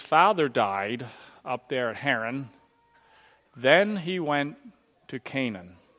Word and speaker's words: Haran: And he came out of the land of the Chaldeans and dwelt in Charan, which is actually Haran, it's just a pronunciation.